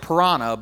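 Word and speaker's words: Paraná: But I had the opportunity years ago when I was visiting Argentina to go fishing in the it's it's pronounced like Parana, but it's not spelled the same. But it's the Parana River uh, on the border of I believe it's Argentina, Paraguay and Brazil.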